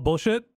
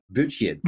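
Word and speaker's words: Buchheit: Paul Bouchit, hopefully I say his name right, Bouchit. He was actually the inventor of Gmail and a very early Google employee. – Wait, Paul Bullshit? – Bouchit, B-U-C-H-E-I-T.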